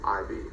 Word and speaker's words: Saraqib: Sarakib. – Yeah, that's wrong. – Saraqib.